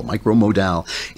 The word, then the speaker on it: Micromodal: They're made out of this stuff, Lensing Micromodal. Rhymes with pal, Micromodal.